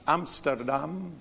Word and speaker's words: Amsterdam: I remember when I was in Amsterdam.